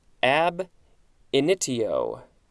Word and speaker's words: ab initio: A-G-G-L-U-T-I-N, dash, ability. Next entry, looks like it's two words, ab initio. Is that pronounced correctly? Ab init, initio, oh, initio, initio. Ab initio. A-B space I-N-I-T-I-O.